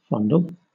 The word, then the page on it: fondle: British English